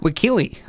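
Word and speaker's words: Wakile: So Kathy Wakeel, how do you say her name? Wakeel? – Wakeely. – Wakeely.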